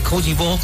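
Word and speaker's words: Côte d'Ivoire: Ya hay alineaciones por parte de Alemania ante Koji Bog, así se pronuncia en François.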